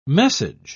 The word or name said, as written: message